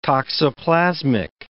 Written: toxoplasmic

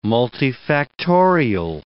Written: multifactorial

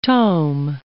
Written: tome

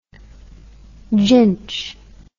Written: ginch

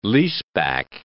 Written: leaseback

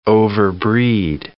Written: overbreed

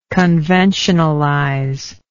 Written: conventionalization

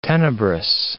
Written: tenebrous

